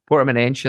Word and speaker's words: Portimonense: I actually looked up how to pronounce it because I was certain that I was doing it wrong. So Portimonense at that point.